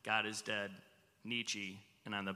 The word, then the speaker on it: Nietzsche: But you actually, gave a really interesting quote from an atheist philosopher named Friedrich Nietzsche. Is that correct? Am I saying that right? – That's right.